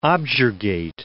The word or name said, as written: objurgate